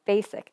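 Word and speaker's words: BASYK: That's honestly, another story, because, I mean, okay, saying that you're a part of a group called Basic, but it's not spelled like how basic the word is spelled, it's awful.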